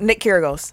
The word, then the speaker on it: Kyrgios: He wouldn't even, he wouldn't be no topping, Kyrgios? – No. – Kyrgios? – I like Nick Kyrgios.